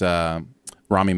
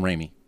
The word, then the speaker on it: Rami: This time it was Rami Malek from Mr. Robot. – Okay. – The main guy from Mr. Robot with the big eyes. I called him Raimi.